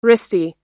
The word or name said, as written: wristy